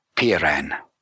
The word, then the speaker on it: Peiran: I'll spell that for you, Mel. P-E-I-R-A-N, Piran. And this association was pulled together of explorers,